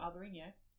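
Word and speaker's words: Albariño: First of all, may I commend you on your pronunciation? – What, Albarino? – Yeah. – It's because I think of Jose Mourinho. – Wow. – Albarino.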